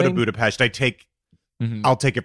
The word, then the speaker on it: Budapest: for saying I was going to Budapest, and then all of a sudden Hayes came out with Budapest, which is Budapest. – Correct. – Yeah, I guess I think it's actually respectful to pronounce it the way that they...